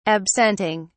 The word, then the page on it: absenting: British English